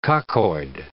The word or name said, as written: coccoid